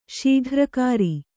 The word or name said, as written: ಶೀಘ್ರಕಾರಿ